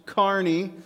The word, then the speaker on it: Kearney: And by the way, if you're listening from elsewhere, this is your very first time, it is pronounced Kearney, Kearney, Nebraska. We got some weird names of cities we live in.